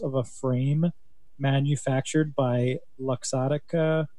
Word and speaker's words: Luxottica: I think that was the correct way to say it, by the way, because there's an O in there. You weren't saying the O before. – Luxottica? – Yeah.